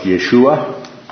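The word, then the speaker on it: Yeshua (ישוע): Kind of the anglicized form and the out of the Hebrew would be Joshua, more specifically pronounced Yeshua, right?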